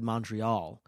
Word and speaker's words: Montreal: You have to kind of swallow the mon and make it sound like mun, a little, Montreal, like you say like that, then you sound Canadian.